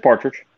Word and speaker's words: Partridge: Or is it pronounced Partridge or is it Patridge? – It's Partridge. Yeah, it's Partridge.